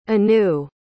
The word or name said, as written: anu